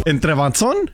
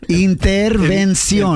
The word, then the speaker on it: intervención: ¿Entrebanzón? – Intervención. – Entrebanzón. – No, es que te está mirando a ti, Shoboy, dijo panzón. – Prima Vani, can you say intervención? – Intervención. – ¿No? – Interversión.